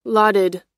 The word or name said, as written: lauded